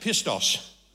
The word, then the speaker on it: Pistos: P-I-S-T-O-S. Now that I've spelt it, I can say it. Pistos.